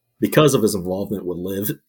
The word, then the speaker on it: LIV: The other headline I had, I don't think you guys have mentioned it before, but of course the LIV, I think it's pronounced LIV, the tour that's the big competitor to the PGA.